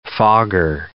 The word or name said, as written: fogger